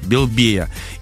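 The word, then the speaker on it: Белебея: Никита, молодец, ударение проставил. Белбея.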